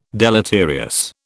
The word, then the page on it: deleterious: British English